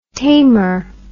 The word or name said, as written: tamer